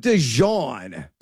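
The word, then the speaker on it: De'Zhaun: And are you, is it DeJuan? Because I've like pronounced his first name like 15 different times. DeJuan Stribling is what we're going with. Is that right? – I go with DeJuan. – DeJuan. Stribling. That's probably right.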